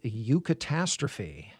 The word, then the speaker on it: eucatastrophe: And if I'm pronouncing it right, it would be eucatastrophe. Exactly right.